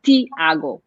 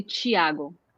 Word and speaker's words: Thiago: Or for example, my cousin, his name is Thiago, but it's a T there. So in northeast, they would say Thiago because it's a T, so you pronounce T.